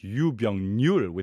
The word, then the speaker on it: 유병률: When you say it all together, it's 유병률 with an N sound at the end, which essentially translates to prevalence rate.